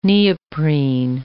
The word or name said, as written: neoprene